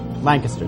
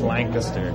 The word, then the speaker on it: Lancaster: Am I pronouncing the town's name wrong? – Yeah, you're good. Lancaster. – Lancaster. – It's not Lancaster, it's Lancaster. – Okay, I'm doing it right.